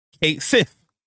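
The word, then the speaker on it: Cait Sith: Square Enix, the Final Fantasy official account, came out and confirmed that in Final Fantasy VII Rebirth, Kate Sith is pronounced Kate Sith, like we all been saying, for years.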